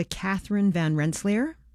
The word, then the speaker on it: Catherine Van Rensselaer: Schuyler was married to Catherine Van Rensselaer. Am I saying her name correctly? – Mm-hmm.